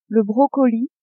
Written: brocoli

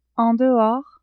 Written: en dehors

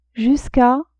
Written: jusqu'a

